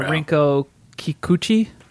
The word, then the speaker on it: Rinko Kikuchi: The lady from Pacific Rim plays Kumiko, Rinko Kikuchi, that's how you say her name.